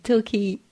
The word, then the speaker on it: Torquay: How do you say Torquay? Torquay.